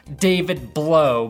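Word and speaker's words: David Blough: The story here, besides Mitch Trubisky having his second coming out party, was that the Lions had to start David Blow, pronounced David Blow by me and anyone who cares about the integrity of football.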